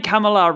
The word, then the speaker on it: Kamala: And I mean, most recently in Kamala, am I pronouncing that right? Kamala Harris's? – Oh, Harris, yes, the vice president. Yep. – Yeah. But I'm saying Kamala, right? – Yes.